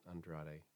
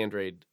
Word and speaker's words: Andrade: And before we switch gears one more time, uh, I had mentioned that Margot Got a ball from Billy Andrade, not Andrade. Again, sorry, Mexican pronunciation. So Billy Andrade uh,